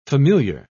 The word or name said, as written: familiar